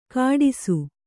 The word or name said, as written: ಕಾಡಿಸು